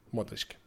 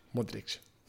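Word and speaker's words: Modrić: Modric. Modric.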